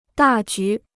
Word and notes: 大局: dà jú

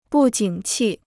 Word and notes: 不景气: bù jǐng qì

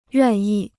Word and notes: 愿意: yuàn yì

